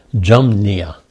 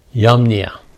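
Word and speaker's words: Jamnia: Now, in most languages, you don't pronounce the J as we do in English. So in English, you'd pronounce it Jumnia. But in most languages, the J is just like the H. It's just a breath sound. And it's Yamnia.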